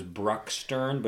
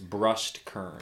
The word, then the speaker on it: Brustkern: Who I used to think in my head was Bruckstern, but it's Brustkern. – Brustkern. It's very like Brustkern. – Yes, that sounds German-esque.